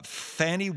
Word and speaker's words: Fani: Fannie, it almost sounds like her name might have been Fania in a longer version of it, but it's spelled F-A-N-I. It's pronounced as if it were F-O-N-N-Y, much like the Eastern European version of the name.